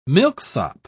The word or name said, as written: milksop